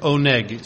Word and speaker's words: oneg: The word delight, the Hebrew word translated delight in Isaiah 58:13-14, is the Hebrew word oneg, spelled O-N-E-G.